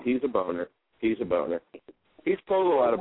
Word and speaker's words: Boehner: Congressman Boehner. It's B-O-E-H-N-E-R, Boehner.